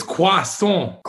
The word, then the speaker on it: croissant: But then after that, it's croissant. Say it right. – Croissant. I know they just go hard on the on part.